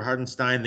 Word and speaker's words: Hartenstein: So what did you think of the move for Hartenstein? They obviously get another, you know, a pair of second round picks. What? Did I say it wrong again? – No, you said it right.